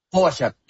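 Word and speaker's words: Pogačar: que es Pogacar, a ver si se pronuncia. – Pogacar.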